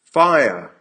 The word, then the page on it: fire!: American English